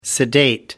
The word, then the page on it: sedate: American English